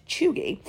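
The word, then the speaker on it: chuegy: According to the New York Times, choogy is pronounced choogy, and it can be used broadly to describe someone who is out of date or trying too hard.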